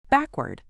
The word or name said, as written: backward